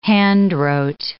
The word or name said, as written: handwrote